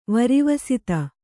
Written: ವರಿವಸಿತ

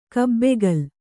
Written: ಕಬ್ಬಿಕೋಲು

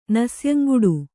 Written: ನಸ್ಯಂಗುಡು

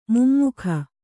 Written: ಮೊಮ್ಮಗ

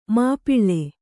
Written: ಮಾಪಾಳ

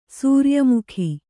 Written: ಸೂರ್ಯಮುಖಿ

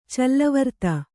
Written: ಚಲ್ಲವರ್ತ

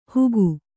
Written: ಹುಗು

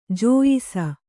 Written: ಜೋಯಿಸ

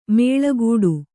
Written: ಮುಳ್ಳುಗಿಡ

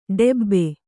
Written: ಡೆಬ್ಬೆ

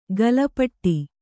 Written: ಗಲಪಟ್ಟಿ

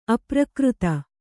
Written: ಅಪ್ರಾಕೃತ

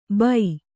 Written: ಭೂ

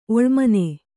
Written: ಒಳಮನೆ

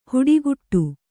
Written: ಹಿಟ್ಟುಗುಟ್ಟು

